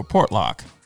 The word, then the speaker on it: Portlock: Walker, have you heard of a guy named Valerie Portluck? – What's that again? – Valerie, V-A-L-I-E-R-Y. I'm probably pronounced that wrong. Portluck. Portluck. – I have not. – All right. Or Portlock, I should say.